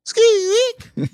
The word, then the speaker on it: Skweek: It's spelled S-K-W-E-E-K, but I read it as Squeak because I have the radio on in the car a lot.